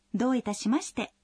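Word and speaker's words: до итасимасте (どういたしまして): Когда произносите «до итасимасте», обратите внимание, что в первом слоге гласное «о» долгое.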